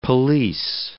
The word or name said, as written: police